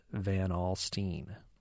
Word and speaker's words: Van Alstyne: And one more vote from Mike, who says the town spelled V-A-N-A-L-S-T-Y-N-E. Looks like Alstein, right? But it's pronounced Van Alstein.